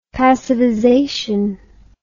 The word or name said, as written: passivization